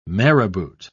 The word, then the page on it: marabout: British English